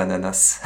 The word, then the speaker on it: ananas: Voilà, donc si on croit la blague, il y a pas, on ne prononce pas le S à ananas.